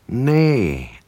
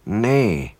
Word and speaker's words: nay: To ask what someone is, we replace she with nay. Although it looks like knee when it's spelled N-double-E, it's actually nay.